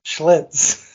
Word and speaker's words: Schlitz: I didn't even know how to say it. Schlitz. Oh yeah, I watched all the commercials. I love calling Schlitz. – Yeah, it was just a little mix up of the L and the I there.